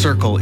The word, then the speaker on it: CRCL: CRCL, pronounced Circle, is a mobile, community-led crisis response service in the Comox Valley.